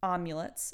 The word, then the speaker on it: amulet: Amulet and you're, it's amulet. – It's amulet. Yeah, you're pronouncing it like omelet.